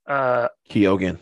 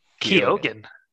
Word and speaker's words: Keoghan: And also, I like Barry Keoghan, however you say his name. – Keoghan. Let's just get it right, because I've heard you guys butcher. – Really? – It's Barry. I think Keoghan. – Yeah, Barry Keoghan. – Keoghan. Okay.